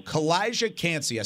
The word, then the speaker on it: Calijah Kancey: Calijah Kancey.